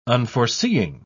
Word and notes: unforeseeing: British English

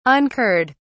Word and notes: incurred: British English

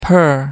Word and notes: per: American English

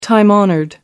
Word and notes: time honoured: American English

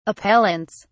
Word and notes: appellants: British English

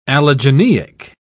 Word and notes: allogeneic: British English

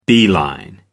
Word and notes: beeline: American English

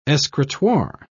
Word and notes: escritoire: British English

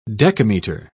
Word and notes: decametre: British English

